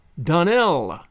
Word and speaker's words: Donnell: Donnell, accent on the last syllable, and St. Clair, on no account, Jacob.